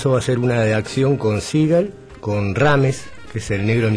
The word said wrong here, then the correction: Rhames: Ah, Ving Rhames, Marcelo Wallace de... – Sí, perdón por mi pronunciación.